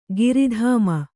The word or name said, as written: ಗರುತ್ಮ